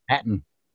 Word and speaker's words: Hatton: Hatton.